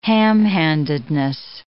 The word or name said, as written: ham-handedness